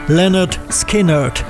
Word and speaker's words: Lynyrd Skynyrd: Im Titel der Scheibe liefern sie die korrekte Aussprache ihres Namens gleich mit. Pronounced Lynyrd Skynyrd.